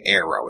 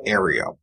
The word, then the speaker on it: Aereo: I'm pronouncing it Aero. It's A-E-R-E-O. – Probably Aereo. – Aereo? – Well, there's an extra E in there.